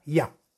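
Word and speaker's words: Yah: Se, se, se deshará de sus enemigos, se liberará de ellos y deberá meditar el, sal, el santo nombre de Yud Hei, que se pronuncia Ya.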